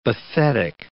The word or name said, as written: bathetic